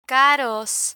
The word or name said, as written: caros